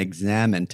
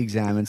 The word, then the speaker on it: examen: Now, I know that the first little hiccup is that we pronounce it a little differently. I know you guys say the examen, right? – Examen. St. Louis is hard A. Is that right, Luke? – Yeah, the hard A.